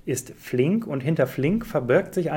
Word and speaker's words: Flink: Und es geht um Flink.